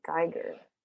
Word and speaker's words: Geiger: A Geiger counter. Did I say that right? Geiger. – Geiger.